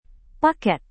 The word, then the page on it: paket: Indonesian